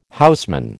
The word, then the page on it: houseman: American English